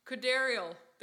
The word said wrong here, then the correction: Cordarrelle: Cordarrelle Patterson.